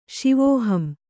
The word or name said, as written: ಶಿವೋಹಂ